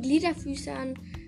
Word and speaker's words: Gliederfüßer: Die Gliederfüßler. Kreaturen, ähm, die zu den Gliederfüßern, nicht Gliederfüßler, sondern Gliederfüßer. Ne? Das ist eine komische Aussprache, nicht das L dazu zu geben.